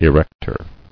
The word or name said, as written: Erector